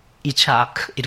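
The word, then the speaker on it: 이차크 (יצחק): 그리고 이 히브리어에서 이삭에 해당하는 그 단어를 어떻게 발음하냐면 이차크 이렇게 발음합니다. 이차크.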